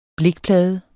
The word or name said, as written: blikplade